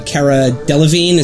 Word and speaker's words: Delevingne: She's an ancient spirit that is inhabiting the body of the archaeologist who discovered her, played by Cara Delevingne.